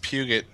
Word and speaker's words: Peugeot: I thought it was a Peugeot. – Hold on here. Let me take a look. – It's pronounced Puget.